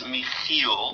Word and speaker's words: Michiel: It's a Dutch name, so it's Michiel.